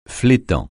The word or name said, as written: flétan